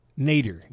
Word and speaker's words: nadir: Nadir. Yes, it's Nadir. Correct. That's what Eager Ernie said, right? Nadir. Pronunciation. I was pronouncing it wrong. – Nader. Yeah. – I said Nadir. Nadir. I put the accent on the wrong syllable.